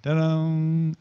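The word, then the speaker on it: Tudum: Speaking of Netflix, Netflix is hosting a fan event called Ta-dum, you know, after the ta-dum. It's on September. Yeah, uh-huh. Yeah, that's how you say it.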